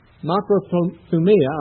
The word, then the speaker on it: makrothumia: Makrothumia, I'll spell it if you're wanting it in your notes. It's M-A-K. This is, of course, the English characters, not the Greek. M-A-K-R-O-T-H-U-M-I-A, with the accent on the I. So it's makrothumia.